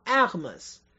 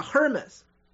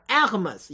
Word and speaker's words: Hermès: 法语当中很多 H 不发音，所以正确的读音叫 Hermes，不是美国人讲的 Hermes 啊，叫 Hermes，一定要记住啊 Hermes。